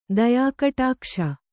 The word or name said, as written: ದಯಾಕಟಾಕ್ಷ